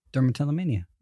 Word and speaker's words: dermatillomania: Your merch just says dermatillomania. – I think that's the first time you've ever said it, and I'm proud of you for saying it correctly. It's a hard word to say. – Dermatillomania.